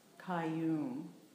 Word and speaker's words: Qayyum: And Kayum is, if you were to spell it phonetically, you would spell it with a K, but you would all, it's actually spelled in English Q-A-Y-Y-U-M, Kayum.